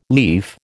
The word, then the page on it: lief: American English